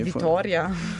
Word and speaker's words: Vitória: Ma in realtà questa pronuncia non è più russa che brasiliana. – Più russa, è Vitória.